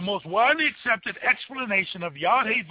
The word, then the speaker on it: Yahweh: Overwhelmingly, scholar opinion holds that the Tetragrammaton was in Moses' time pronounced Yahweh.